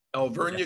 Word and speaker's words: Alvernia: Now, I always butcher the name, Alvernia. – Yes. – Alvernia, yes.